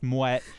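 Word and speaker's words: Moët: And I just want to say that we've talked about Moet before. It's not Moe, it's Moet.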